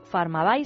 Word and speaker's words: Pharmabuy: Farmabay se escribe Pharmabuy.